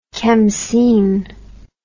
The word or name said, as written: kamseen